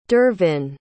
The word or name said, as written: dervin